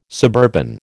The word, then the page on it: suburban: American English